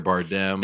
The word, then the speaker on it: Bardem: That was in the rehearsal script that I was, and I kept saying Javier Bardem. Bardem, I was saying it wrong. My wife was like, it's them, like Democrat, Bardem.